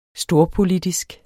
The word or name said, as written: storpolitisk